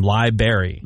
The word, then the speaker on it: library: Which Menace says all the time, library. – Library? Mm-hmm. – Yeah, like there's a blueberry, a strawberry, a library. – Okay, wait, say library. Hold, everybody stop. Say it correctly.